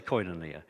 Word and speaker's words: koinonia: It comes from a word called, which is a koinonia, depending on which way you come from. It's a bit like the Adelaide and the South Australian and the New South Wales pronunciations. Let's say koinonia.